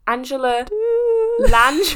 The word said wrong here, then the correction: Angela Lansbury: The teapot in Beauty and the Beast. – Taylor's oldest. Angela Lansbury. – Angela Lansbury.